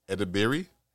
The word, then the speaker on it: Edebiri: Ayo, Ayo Edet, I don't know how she's pronounced her name. Ayo Edebiri, she won for The Bear, an excellent show.